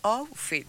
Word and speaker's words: outfit: Y así con ese luquete, con ese outfit. No sé si lo digo bien. – Sí. – La Maggie se puso los anteojos, el outfit.